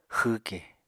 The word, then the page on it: xwwke: Apatani